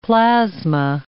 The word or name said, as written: plasma